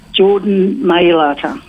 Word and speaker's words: Mailata: Is it Jordan Mailata? – Ah, yeah.